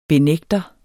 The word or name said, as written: benægter